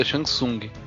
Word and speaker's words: Shang Tsung: É uma, só uma correção, eu acho que o pronúncio correto é Shang Tsung. Shang Tsung, com um T mudo, se não me engano.